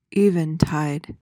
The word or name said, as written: eventide